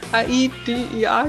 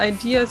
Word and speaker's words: AiDiA: dass es Idea ausgesprochen ist. Also nicht, ich habe immer davor A-I-D-I-A gesagt, aber es ist Idea, so wie die englische Idee.